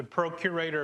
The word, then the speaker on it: procurator: And as a result of that, Caesar then gave him the responsibility uh, to be the procreator of, um, oh, did I say that right? I didn't say that.